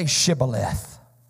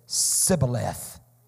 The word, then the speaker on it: Shibboleth: Jephthah's standing there and he's saying, they're saying, if you want to cross this river, if you want access to this flow, say Shibboleth. And the Bible said that the man from Ephraim could not frame his mouth to pronounce it. And he said, Sibboleth.